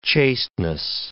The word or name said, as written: chasteness